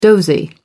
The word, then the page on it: dozy: American English